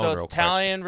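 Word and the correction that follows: Taillon: versus Caprillion. Did I say that right? Talion. – Tyone versus Caprillion, Cole versus Manaea, Cortez versus Montas, and Montgomery versus Blackburn.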